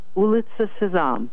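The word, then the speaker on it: Ulitsa Sezam: So how do you pronounce it again? Ulitsa Sazam? – Ulitsa Sazam. – Sazam. Sesame Street in Russia.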